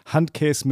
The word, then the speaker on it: Handkäse: Aber ich mag auch die Sachen, die ihr auf der Speisekarte habt, sowas wie Handkäse mit Musik, immer ganz, ganz wichtig, die Betonung auf der ersten Silbe.